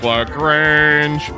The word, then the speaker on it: LaGrange: There's Dominic LaGrange, which rhymes with strange.